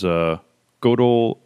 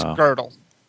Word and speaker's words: Gödel: Number four was Gödel, Escher, and Bach. I don't even know if I pronounced that right. – It's Gödel.